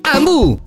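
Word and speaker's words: Hambu: Ambu si scrive con l'H.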